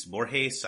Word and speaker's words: Borges: which is Ficciones by Jorge Luis Borges.